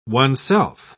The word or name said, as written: oneself